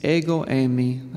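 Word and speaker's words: ego eimi (ἐγώ εἰμι): In the Greek, which is what the gospel was originally written in, it's ego eimi. That's how you pronounce it in the Greek.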